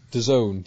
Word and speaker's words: DAZN: DAZN, D-A-Z-N.